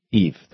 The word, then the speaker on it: Yves: A piece by, how do you pronounce Y-V-E-S? Eve, thank you.